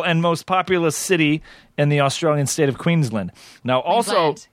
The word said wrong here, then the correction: Queensland: Queensland. Sorry, I know. Your emphasis is just on the wrong syllable, but you did pretty good.